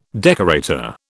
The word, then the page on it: decorator: British English